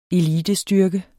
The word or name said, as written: elitestyrke